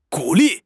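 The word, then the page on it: gu3 li4: tones 3, 4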